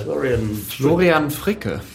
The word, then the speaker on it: Florian Fricke: Moving on to Sounds 81, the wonderful Sandy Robertson interviewing Purple View's Florian Frick. You'd be able to pronounce that better. – Florian Fricke.